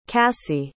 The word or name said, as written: casee